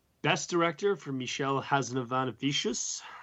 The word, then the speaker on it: Michel Hazanavicius: It's Michel Hazanavicius. I think I added an extra syllable in there by mistake because it's rolled off the tongue as such. But Michel Hazanavicius is the way you say it.